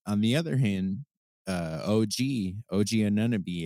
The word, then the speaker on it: Anunoby: Have I been saying his name wrong? – Anunoby. No, I just said it really goofily. – Okay. No, my fault. I'm not trying to call you out. Yeah. I thought I was saying it wrong.